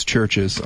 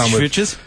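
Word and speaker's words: CHVRCHES: I don't know if you heard yesterday, I did mention how you guys can't pronounce churches on Chvitches? – Chvitches, yeah.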